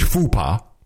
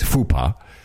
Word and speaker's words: faux pas: And he makes a huge faux pas. As you know, that's the way you pronounce it these days. Faux pas.